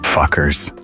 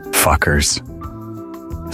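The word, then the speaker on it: Fcukers: The name of this rising electronic dance punk band is pronounced as Fuckers. Fuckers. Despite the unconventional spelling of the first four letters, the name is pronounced exactly like the common English profanity.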